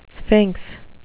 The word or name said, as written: sphinx